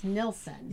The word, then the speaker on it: Nilsson: Nielsen and the original performer Neil. – Yes, and many of Harry Nilsson's songs have been used in film soundtracks. – Do I keep mispronouncing his name? It's Nilsson.